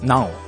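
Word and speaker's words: não: そうですね、否定をするときは non を必ず前につけるので、はい、 nao と書いて a の上ににょろっとした記号がつくということです。 non と言いますね。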